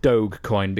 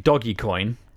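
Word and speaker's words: Dogecoin: You know, the one way it isn't pronounced that you said last week, doggy coin. – Loads of people think it is. – They're wrong. Nothing. Find me another word that has O-G-E in it that's pronounced doggy. There isn't one.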